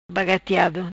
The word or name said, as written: Bagatiado